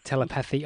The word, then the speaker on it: telepathy: I hate the word telepathy. I'm not actually sure how you meant to say it. – Telepathy? – Telepathy sounds much better. – Yeah. – I was putting the emphasis on the wrong syllable.